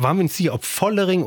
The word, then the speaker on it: Vollering: Zwischen F und V, Vollering. – Zwischendrin, Immanuel Vollering.